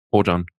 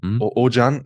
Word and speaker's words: Oajan: Es wird geschrieben O-A-J-A-N. – Ojan. – Ojan, ja.